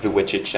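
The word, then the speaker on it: Vujicic: His last name is really hard to spell, so it's pronounced Dwichich.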